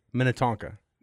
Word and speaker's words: Minnetonka: Minnetoka, I think. – Nope, we got to say it right. Minnetonka.